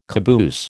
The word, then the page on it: caboose: American English